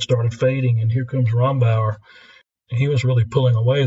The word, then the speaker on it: Rombauer: is it Rombauer? Am I saying that correctly? That's right.